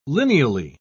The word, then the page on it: lineally: British English